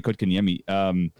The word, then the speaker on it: Kotkaniemi: Sitting Kotkaniemi, I can never pronounce that correctly, Jesperi Kotkaniemi. – That's pretty good.